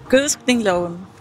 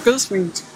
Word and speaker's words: Gødskningsloven: Gødskningsloven. Eller, ja, man gødsker noget, men så gødskningsloven. – Ja, der står gødskningsloven.